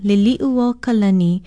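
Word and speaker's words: Liliuokalani (Liliʻuokalani): Aloha, our Hawaiian word of the day is a well-known name, Liliuokalani. It is spelled L-I-L-I-O-K-I-N-U-O-K-A-L-A-N-I. She was our last queen, overthrown in January 1893. Many mispronounce her name because of familiarity with the English name Lily. Remember, it's Liliuokalani.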